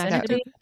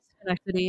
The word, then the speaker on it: Schenectady: Schenectady. Schenectady. – Oh, that's how you say it? – Schenectady, yep.